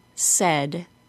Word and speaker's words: said: The word say, S-A-Y, is pronounced with a long A. Can you hear it? Say. The words says and said are pronounced with a short E sound.